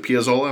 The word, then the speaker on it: Piazzolla: And then we get to the one at the end, and is it Piazzolla? Am I saying that right? – Yeah.